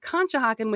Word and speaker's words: Conshohocken: So first, we said it wrong. We thought it started with a P. It is, in fact, Conshohocken with a C.